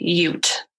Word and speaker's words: Ute: So in the episode, I pronounced it as Ute, and it's actually Ute. – Oh. – Like Utah.